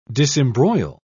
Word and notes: disembroil: British English

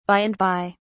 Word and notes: by and by: British English

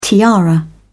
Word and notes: tiara: British English

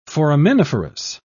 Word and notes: foraminiferous: British English

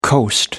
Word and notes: coast: American English

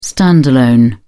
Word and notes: stand-alone: British English

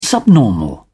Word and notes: subnormal: British English

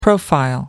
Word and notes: profile: American English

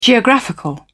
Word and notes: geographical: British English

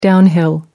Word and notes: downhill: American English

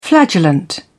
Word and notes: flagellant: British English

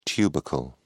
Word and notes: tubercle: British English

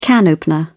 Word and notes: can opener: British English

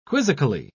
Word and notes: quizzically: British English

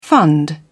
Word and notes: fund: British English

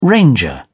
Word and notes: ranger: British English

